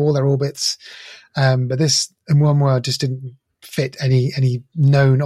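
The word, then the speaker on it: Oumuamua (ʻOumuamua): I think that's the correct way of saying it. Oumuamua. It's a Hawaiian word and it means scout.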